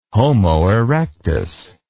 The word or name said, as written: homo erectus